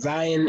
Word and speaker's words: Zion: I think Zion, Zion, I believe is how it's supposed to be pronounced. I keep forgetting. Zion is healthy, and Brandon Ingram continues to grow.